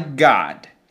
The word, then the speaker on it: God: See that in American English, that O is not pronounced as a closed sound. It is more open. It's an O that wants to be an A. God. But in British English, they do pronounce an O.